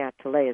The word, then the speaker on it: Atelier: The Healing Atelier is how it's pronounced, but it's spelled like, if you do it phonetically, atelier.